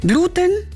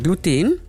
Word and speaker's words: Gluten: Laut Duden liegt die übliche Variante auf dem E, also Gluten, in Anlehnung an andere chemische Endungen wie zum Beispiel Arsen. Das lateinische Wort für Leim bzw. Klebstoff ist aber Gluten. Und da liegt die Originalbetonung auf dem U.